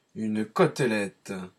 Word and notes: chop: American English; [ˈtʃɑːp]

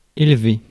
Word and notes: élever: [ɛl.ve]; France

